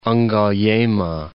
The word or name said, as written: Mount Ngaliema